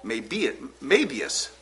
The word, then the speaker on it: Möbius: So a Möbius strip is when you Möbius, the accent on the first syllable, give it half a twist, like this.